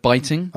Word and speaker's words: biting: Yeah, well, like a classic example, the other day, my wife said the word bitting instead of biting. – Uh-huh. – I mean, to be fair, it's spelt B-I-T-I-N-G.